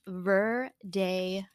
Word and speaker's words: Verdejo: It's pronounced Verdejo.